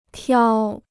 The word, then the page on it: 挑: tiǎo